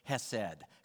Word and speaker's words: chesed: And that term, we talked about this if you were with us last week, is chesed. Chesed, if you want to say it like a local speaker.